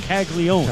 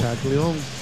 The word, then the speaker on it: Caglianone: It's spelled C-A-G-L-I-A-N-O-N-E, like Caglianoni, but it's just Caglione. – Caglione.